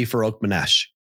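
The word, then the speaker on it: Farokhmanesh: Ali Farouk Manesh.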